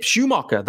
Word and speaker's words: Schumaker: We haven't spoken about the managerial hire, Skip Schumaker. That is the pronunciation, by the way,